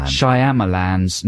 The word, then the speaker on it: Shyamalan: Shyamalan's new film, The Happening, which is all about sort of nature taking revenge on man or something like that.